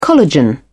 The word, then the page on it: collagen: British English